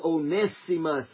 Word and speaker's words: Onesimus: But Onesimus, I get to saying Onesimus a lot of times and don't mean to, but the correct pronunciation is Onesimus, amen.